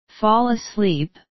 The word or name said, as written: fall asleep